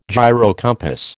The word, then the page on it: gyrocompass: American English